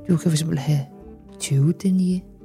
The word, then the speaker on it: denier: Man har dem jo typisk i forskellige denier, tror jeg, det udtales, hvis du ved, hvad det er. – Hvad er denier? – Det er denier.